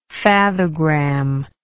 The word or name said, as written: fathogram